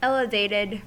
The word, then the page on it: elevated: American English